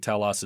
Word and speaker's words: telos: Today's topic or today's word is telos. It does go by different pronunciations, but I think telos is probably the most common one.